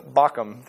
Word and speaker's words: Bochim: to Bochim. That's how you say it.